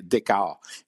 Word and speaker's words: Dichow: Ça s'écrit D-I-C-H-O-W, mais il faut prononcer Descartes.